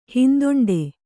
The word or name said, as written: ಹಿಂದೊಂಡೆ